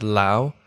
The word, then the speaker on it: Lyu: And then my last name, I've always said Lau, it's L-Y-U, but I guess it's like Liu, I get a lot, like Liu.